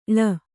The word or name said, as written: ೞ